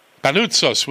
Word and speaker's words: Panoutsos: Panoutsos.